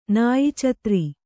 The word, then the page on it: ನಾಯಿಚತ್ರಿ: kannada